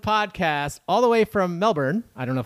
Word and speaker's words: Melbourne: I said the name right, and how do you pronounce where you live? – Melbourne.